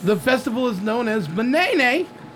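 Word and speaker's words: Ma'nene: You should have waited because you could have been a part of today's festivities, which I believe is pronounced, let me get the proper way to pronounce it, Manene, which is M-A hyphen N-E-N-E.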